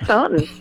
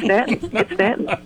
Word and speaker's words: Staunton: Don't you say Staunton. It's Stanton.